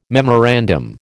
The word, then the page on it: memorandum: American English